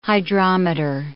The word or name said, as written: hydrometer